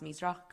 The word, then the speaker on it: Measroch: Measroch correctly, because not everyone can, but it's just a testament to our long friendship.